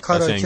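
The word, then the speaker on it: کاراکی (カラオケ): در مورد کاراکی باید بگم که تلفظ شما کاملا درسته. من به عنوان ایرانی که 26 ساله تو ژاپن زندگی میکنم، کاراکی همونی که شما میگین درسته.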